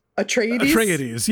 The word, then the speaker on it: Atreides: Atreides, yes, for sure.